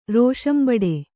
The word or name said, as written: ರೋಷಂಬಡೆ